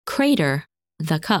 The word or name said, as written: Crater